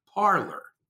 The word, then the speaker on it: Parler: I think they are mispronouncing the French word, parler. I mean, pronounced parler, which is the verb for talk.